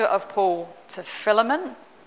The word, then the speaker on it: Philemon: Said it right, Bethany? So many people have told me down the years it's Philemon. It's not, because it's from the Greek word phileo, so it's got to be a phil.